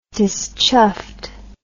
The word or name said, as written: dischuffed